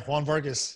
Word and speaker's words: Juan Vargas: Yeah, Juan Vargas.